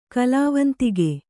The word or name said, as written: ಕಲಾವಂತಿಕೆ